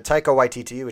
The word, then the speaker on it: Waititi: And I thought it was kind of interesting that Taika Waititi, which is apparently how you pronounce it, that's good to know.